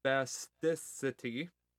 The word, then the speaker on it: spasticity: I want to make sure I'm saying this word right, because I know if I say it wrong, it's actually very offensive. Spasticity associated with multiple sclerosis. There we go.